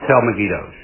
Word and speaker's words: Megiddo: When you read Armageddon in Revelation, it's a mispronunciation of Megiddo. Right, so Har means valley. So it's the Valley of Megiddo becomes Armageddon just because they pronounce it wrong eventually.